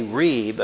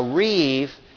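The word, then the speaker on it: rîb: Well, what you also have is what is called a reeb, except the B here is pronounced like a V. A reeve is an accusation format that the prophets use to accuse the people of violating a covenant.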